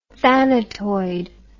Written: thanatoid

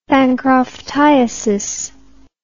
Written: bancroftiasis

